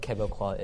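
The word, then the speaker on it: Québécois: Okay, maybe explain what Quebecois is. Because they might be thinking it's Quebecers. But not. – Quebecois is the right pronunciation of Quebecers.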